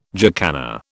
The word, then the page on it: jacana: British English